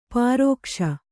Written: ಪರಿಘೋಷ

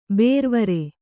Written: ಬೇರ್ವರೆ

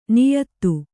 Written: ನಿಯತಿ

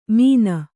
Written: ಮೀನ

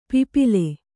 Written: ಪಿಪ್ಪಾಲ

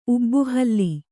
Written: ಊಬುಹುಲ್ಲು

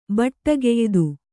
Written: ಬಟ್ಟುಗೆಯ್ದು

